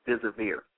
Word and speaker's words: deservir: The word deserve comes from an old French word, and it's pronounced deservir.